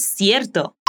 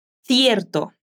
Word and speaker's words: cierto: es cierto. Atención a la pronunciación. Bea es de España, así que dice cierto con la lengua entre los dientes. Repite, cierto.